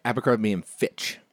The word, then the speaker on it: Abercrombie & Fitch: Abercrombie & Fitch.